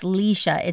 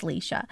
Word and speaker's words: Laois: So it's Leisha. It's spelled L A O I S, but the Gaelic pronoun- pronunciation is Leisha.